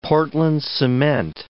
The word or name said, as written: portland cement